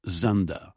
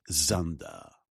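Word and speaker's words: Dzundza: His name is George Zunda. It's pronounced Zunda.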